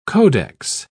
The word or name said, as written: codex